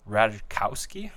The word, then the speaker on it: Ratajkowski: We Are Your Friends with Zac Efron and Emily Ratajkowski that's how you say it.